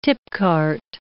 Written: tipcart

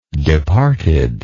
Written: departed

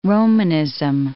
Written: Romanism

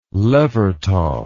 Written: Levertov

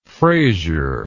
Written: frazier

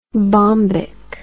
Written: bombic

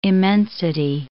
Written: immensity

